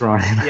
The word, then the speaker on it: Rian: Ryan Johnson or whatever you want to say his name is. Right, it's Ryan.